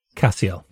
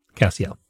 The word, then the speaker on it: Cassiel: It's Cashel, right? Is that how we say pronounce it? – Cassiel. – Is it Cassiel? All right. – Cassiel.